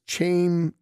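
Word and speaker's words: Chaim: And it's C-H-A-I-M. If everybody remembers episode 189, I couldn't figure out how to pronounce his name. It's Cham Bloom, and it's C-H-A-I-M.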